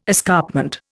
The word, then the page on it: escarpment: Indian